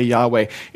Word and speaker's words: Yahweh: and that's where we get the name Yahweh. It's how it would be approximately pronounced. There's missing vowels, and most pronunciations, most Hebrew scholars would say Yahweh.